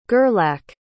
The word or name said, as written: gerlach